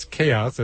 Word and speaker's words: KAOS: A lot of people get confused about that when they see the poster, but it's chaos as in, you know, insanity. – What this show is crazy. But it's Chaos for Chaos, and it's spelled KAOS, which stands for the Kimberly Ann O'Neill Scholarship.